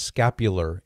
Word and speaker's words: scapular: So first of all, the word is pronounced scapular. There's an R at the end, scapular.